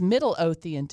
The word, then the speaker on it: Midlothian: Oh, Midlothian is Middle Othian to people who grew up there.